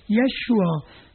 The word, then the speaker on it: Yeshua: Unser Erlöser heißt eigentlich nicht Jesus, wie ihn die Griechen nannten, sondern er heißt Joshua, oder eben anders ausgedrückt auch, oder anders ausgesprochen, Yeshua.